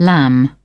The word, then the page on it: lamb: British English